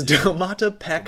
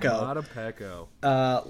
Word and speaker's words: Domata Peko: So, I'm not 100% sure on the pronunciation, but I believe this is Domata Peko. – You nailed the vowels. You nailed the consonants. Look, it is Domata Peko.